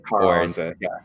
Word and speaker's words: Karaonza: Karanza, K-A-R-A-O-N-Z-A.